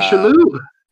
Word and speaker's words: Shalhoub: Vincent D'Onofrio, the guy that plays that plays Kingpin in Daredevil, was in it, and same thing with like I think Tony Shalhoub, or I don't know how to say his last name. – Tony Shalhoub.